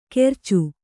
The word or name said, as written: ಕೆರ್ಚು